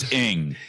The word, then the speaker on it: Aang: Stop mispronouncing those names. You're going to cause people to have some serious. – Everybody knows it's Aang.